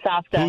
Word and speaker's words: Safta: Saba and Safta.